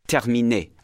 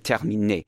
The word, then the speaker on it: terminé: Terminé, which means terminated. Terminé. Français. Inscrit. The E sound is very short.